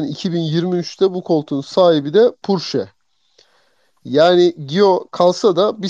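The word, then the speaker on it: Pourchaire: Ondan sonra e, o oturur mu oturmaz mı bilmiyorum ama e, bu po- nasıl okunuyor İskoç bu şey? Theo Purshari diye mi okunuyor bu çocuk? Purşe.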